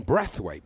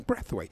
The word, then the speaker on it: Braithwaite: And Clive, who checks these things, kept calling the Barcelona forward Martin Brathwaite, because that's how he pronounced his name, Martin Brathwaite.